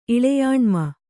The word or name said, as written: ಇಳೆಯಾಣ್ಮ